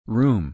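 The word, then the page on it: room: /rú:m/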